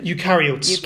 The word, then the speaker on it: eukaryotes: And then at some point, they sort of joined together to create a new type of cell, and those are called eukaryotes. Am I pronouncing that right? – Eukaryotes.